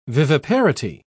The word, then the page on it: viviparity: British English